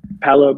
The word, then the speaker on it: Paolo: Paolo Banchero.